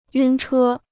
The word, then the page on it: 晕车: yùn chē